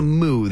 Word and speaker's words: MOU: I like calling it a moo. The MOU. I'll call it a moo.